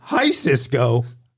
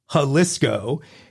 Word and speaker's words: Jalisco: He later amended that to to Hi Cisco, wrong. And then he went for the grammatical hat trick, telling me it was Hal Cisco. Wrong for the third time. The correct pronunciation is Jalisco.